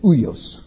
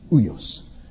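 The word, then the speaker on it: uios (υἱός): And it's said uios, uios.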